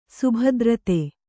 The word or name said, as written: ಶಬ್ದಾರ್ಥ